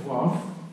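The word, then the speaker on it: вав (ו): Это вав. Вав. Ну, предположительно, это был губно-губной звук, как в английское «уа», как там, «windows», да?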